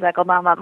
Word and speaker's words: Akeldama: You pronounce it the way I pronounce it, which is Akeldama.